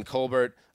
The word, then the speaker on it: Colbert: Steelers GM Kevin Colbert made it official. Did I say that right? – I think it's close, yeah. – It's totally right.